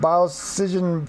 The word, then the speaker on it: Biocision: There's two webpages for his businesses, the Biocision Labs and Biocision Pharma. – Yeah, and so he said, to say it properly, because I was practicing, take the word biology and precision and mush them together. So Biocision.